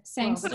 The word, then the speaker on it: Sangster: His name's Thomas Brody Sangeter. Sangster. Sa- well, how do you say it? Sangster. – Sangster.